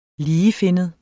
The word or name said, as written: ligefinnet